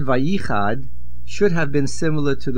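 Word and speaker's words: Vayichad (וַיִּחַדְּ): The main accent is on the Yud Vayichad,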